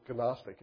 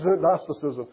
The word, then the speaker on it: Gnosticism: Or the Gnostics, and it's spelled G-N-O, Gnostic, is how I want to say it, but it's Gnosticism.